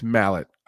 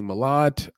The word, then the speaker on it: Mallet: It's Mallet. I've been saying Malat. I've been trying to make it sound more French.